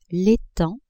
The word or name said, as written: etang